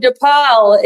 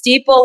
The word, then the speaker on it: Deepal: I've been calling you DePaul. It's Deepal like people.